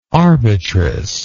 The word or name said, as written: arbitress